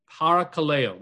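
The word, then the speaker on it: parakaleo (παρακαλέω): This word comforted in the Greek is the Greek word, the Greek verb, parakaleo. Parakaleo, that's the way you'd pronounce it.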